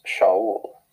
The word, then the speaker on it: Shaul: The name Saul is from the Hebrew word pronounced Shaul.